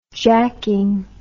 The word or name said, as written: jacking